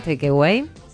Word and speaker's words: takeaway: A ver cómo te salió el takeaway. – Takeaway. – Ay, qué bien, qué pronuncia, qué pronuncia.